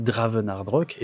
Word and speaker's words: Dravenardrok: Euh, puisque euh, le, nom du compte, que ce soit sur Blue Sky ou Twitter, c'est Dravenardrock, et ça s'écrit D-R-A-V-E-N-A-R-D-R-O-K.